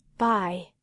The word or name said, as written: by